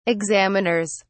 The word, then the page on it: examiners: British English